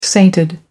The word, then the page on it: sainted: American English